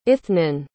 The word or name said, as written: ithnan